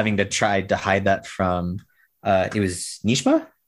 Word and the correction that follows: Najma: I believe her name was? – Najma? Najma. I don't know. – I'm probably butchering that. – Sorry, Najma. – it is Najma. Yeah, I just looked it up now.